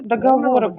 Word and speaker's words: договоры: А также данные фиксирую, чтобы берете, оформляете договора без цели. – Договоры правильно говорить. Ну, что такое договора? – Всего хорошего.